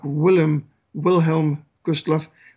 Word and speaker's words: Wilhelm Gustloff: And when I say Willem Gustloff, you pronounce it more like Wilhelm Gustloff. But of course, the Germans would have pronounced the W's as a V. And because we speak Afrikaans here, we do the same thing in South Africa.